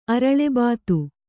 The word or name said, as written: ಅರಳೆಬಾತು